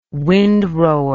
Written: windrower

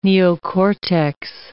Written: neocortex

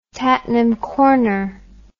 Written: Tattenham Corner

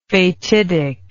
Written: fatidically

